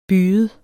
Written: byget

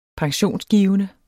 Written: pensionsgivende

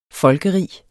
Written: folkerig

